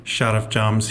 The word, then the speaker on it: Sharavjamts: Easier for the American to be able to say, but definitely learn how to say his last name, Sharavjamts.